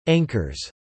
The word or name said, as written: anchors